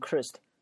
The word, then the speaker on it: cursed: 比如说这个 curse 啊，后面这个 s 不是一个清辅音嘛？那加个 ed 变成 cursed，那叫可恨的或叫诅咒的。